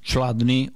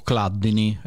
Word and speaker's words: Chladni: È il fisico tedesco Ernst Chladni o Chladni. L'ho sentito pronunciare in tutti i modi, Chladni o Chladni, è CHL.